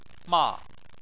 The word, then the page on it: ma: 4